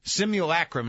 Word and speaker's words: simulacrum: Simulacrum is how it's pronounced, and I looked it up.